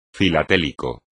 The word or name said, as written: filatélico